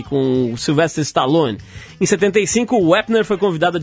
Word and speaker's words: Wepner: está falando de boxe aqui, o ex-pugilista Chuck Wepner está pedindo uma indenização de 15 milhões de dólares a Sylvester Stallone. Gostou da pronúncia, Arthur? Tá impecável.